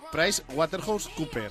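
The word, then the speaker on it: PricewaterhouseCoopers: Que sepáis que es Price Waterhouse Cooper.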